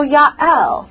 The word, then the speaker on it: Yael: That's pronounced with a Y because there's no J's in Hebrew. So Yael.